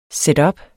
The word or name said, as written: setup